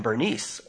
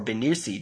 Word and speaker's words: Bernice: Agrippa and Bernice, or Benici, depending on how you want to pronounce it,